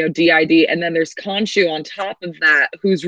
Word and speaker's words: Khonshu: The God Khonshu.